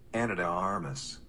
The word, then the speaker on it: Ana de Armas: Ana de Armas.